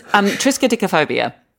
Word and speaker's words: Triskaidekaphobia: Triskaidekaphobia.